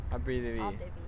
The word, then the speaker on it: Arbre de vie: Abri de vie.